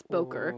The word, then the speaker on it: Bokor: They are dead people revived by necromancy via a Bokor or Bok Bok yeah, I think it's Boker.